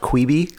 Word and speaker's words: Quibi: it's pronounced Queeby.